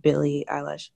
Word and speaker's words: Billie Eilish: That's how you say it, right? Billie Eilish. Eilish. – Yeah.